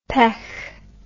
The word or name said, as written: pech